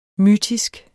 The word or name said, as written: mytisk